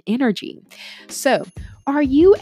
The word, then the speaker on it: Nrgy: Coach Energy. And energy is spelled N-R-G-Y, just in case you're listening to me and you're not looking at it on your web or online or possibly on YouTube. So NRGY.